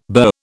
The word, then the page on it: burr: British English